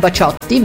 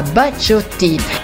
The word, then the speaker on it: Bacciotti: fatto sta che bisogna pronunciarlo alla francese, quindi non Bacciotti ma Bacciotti, che nel 1977 ottenne un buon successo anche in Italia con la canzone Blackjack.